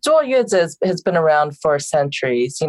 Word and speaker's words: 坐月子: Yes, it's a 坐月子. So it's like 坐, and then 坐 means sit, and then 月子 means month.